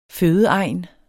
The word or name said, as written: fødeegn